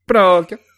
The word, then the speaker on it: Pinocchio: Pinocchio, or if you're in Italy, pronounced Pinocchio, is currently streaming on Netflix for your viewing enjoyment.